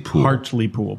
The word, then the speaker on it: Hartlepool: H-A-R-T-L-E-P-O-O-L. – Hartlepool, but it's pronounced Hartlepool.